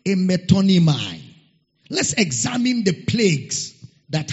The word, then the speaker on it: metonymy: Metonymai. Metonymai. Metonymai. It's spelled as M-E-T-O-N-Y-M-Y.